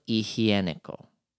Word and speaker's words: higiénico: Papel higiénico. – Higiénico. – Like hygienic. – Higiénico? – Yes. And we spell it H-I-G...